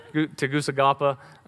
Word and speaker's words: Tegucigalpa: Tegucigalpa? Oh, I've been saying it wrong this whole time. Tegucigalpa.